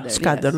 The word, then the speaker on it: Σκάντερ: Μπράβο, κύριε Σάντερ. Πώς σας λένε, δεν ξέρω. – Σκάντερ, νομίζω έτσι προφέρεται.